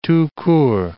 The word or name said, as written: tout court